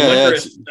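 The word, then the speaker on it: Liacouras: AEW Dynamite, October 6th, Philadelphia, Pennsylvania, the Lyacorus Center. Do you know how that's pronounced? – Lyacorus? Licorice? – Yeah, the Licorice Center campus of Temple University.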